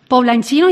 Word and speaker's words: poblanchinos: Pero lo que veo aquí, alcalde, es que mucho trabajo, ¿no?, para devolver la ilusión a los poblanchinos y poblanchinas. ¿Lo he dicho bien? – Sí, efectivamente. Ese es nuestro gentilicio, poblanchinos y poblanchinas. Pues mucho trabajo, mucho trabajo, pero hay que poner en base de que es nuestro trabajo.